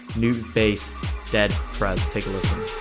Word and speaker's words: Nubass: His name is New Bass, that's spelled N-U-B-A-S-S, and